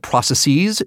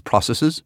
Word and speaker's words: processes: Wundelpark, or I learned that processes is not how you pronounce it. It's processes.